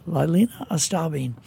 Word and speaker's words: Lylena Estabine: Lylena Estabine.